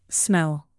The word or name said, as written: smell